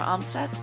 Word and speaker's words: Amstutz: And you can find me on all social media platforms at Sandra Amstutz.